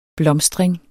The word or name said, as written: blomstring